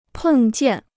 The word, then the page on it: 碰见: pèng jiàn